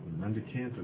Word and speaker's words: mendicant: Oh, I think it's a hard K, a hard C sound. – Mendicant, yep. I looked at the legend of the word and I said, yeah, it's mendicant, which it means a beggar.